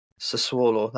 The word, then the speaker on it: Sassuolo: But since pre-season in July, they've only conceded 1 goal, and they've played Fiorentina, they've played VfL Bochum, they've played Sassuolo, I can't never pronounce that one right. – Sassuolo, that's it.